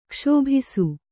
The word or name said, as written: ಕ್ಷೋಭಿಸು